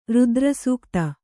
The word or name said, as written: ರುದ್ರಸೂಕ್ತ